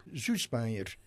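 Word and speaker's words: Süßmayr: Et donc, la femme de Mozart a privilégié, je ne vais pas dire son nom en allemand. – Schussmeier. – Voilà, lui, il sait parler l'allemand, moi pas.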